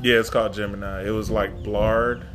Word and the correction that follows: Bard: Bard, I think.